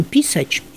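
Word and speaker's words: pisać: Правда, вот по-польски звучит этот глагол с ударением, который свойственен польскому языку «писач».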